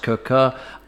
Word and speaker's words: Quooker: It's spelt Quooker, pronounced Cooker.